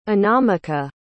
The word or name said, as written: anamika